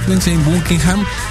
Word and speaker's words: Lindsey Buckingham: Entraría Lindsey Buckingham, así se ha de pronunciar, sí, Lindsey Buckingham,